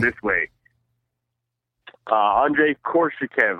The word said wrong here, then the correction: Koreshkov: I butcher his last name all the time. But Andrei Korshchikov. – All right, cool. – Koreshkov. – Oh, Koreshkov.